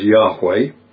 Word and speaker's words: Yahweh: This is talking about Yahweh, sometimes translated Jehovah. More accurately, we think, is pronounced Yahweh.